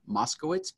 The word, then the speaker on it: Moskowitz: And, but if you follow me on social, you can use my nickname, Spanky, S-P-A-N-K-Y, Moskowitz. Maybe I shouldn't spell that. M-O-S-K-O-W-I-T-Z, Spanky Moskowitz on all social channels, Facebook, Instagram, TikTok, Twitter, you name it, you'll find me there.